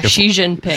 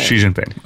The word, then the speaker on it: Xi Jinping: Which, by the way, I think we're pronouncing that right. – Yeah, Xi Jinping. – Xi Jinping. Okay.